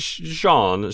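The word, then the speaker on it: Sjón: is it Shong? – Yeah, that's the closest I've come to. – Okay. Yeah, because it's spelled S-J-O-N. It's Sean.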